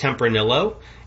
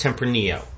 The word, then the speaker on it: Tempranillo: I think it's okay to pull them aside and say, hey, honey, so it's not pronounced Tempranillo. The wine is pronounced Tempranillo.